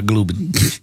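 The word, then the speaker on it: Gulbin: Why do I say Glubin? Is one of us right or one of us wrong? – I haven't looked at a pronunciation yet. I just assume that's how you said it. – It's Glubin. – It's G-U-L-B-I-N. – Glubin. – Glubin? – Yeah. – All right, Glubin.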